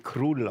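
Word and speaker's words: Król: Es heißt ja König, polnisch. – Ja. – Und dann wird eigentlich Krul ausgesprochen. – Krul. – Wenn man das Krul. – Krul. – Ja. Wie Wutsch, Lodge. – Ah, okay. – O, Akzent, U.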